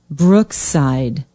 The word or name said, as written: brookside